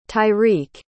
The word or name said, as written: tyreek